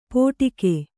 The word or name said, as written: ಪೋಟಿಕೆ